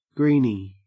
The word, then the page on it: greeny: Australian English